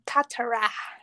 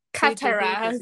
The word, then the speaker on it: Katara: Sí, sí, es, totalmente, lo pronuncian en inglés. Katara, así, Katara.